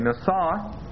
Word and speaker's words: nasa: And that's spelled like this, N, long A, S, long A, nasa.